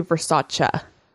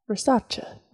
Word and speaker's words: Versace: Okay, so I wanted our listening audience to know that I am aware that actually the correct pronunciation of Versace is actually Versace. – Versace. – Versace. – That sounds Italian, yeah.